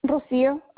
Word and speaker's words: Rocio: Rocio, R-O-C-I-O. – R-O-C-I-O? – R-O-C-I-O.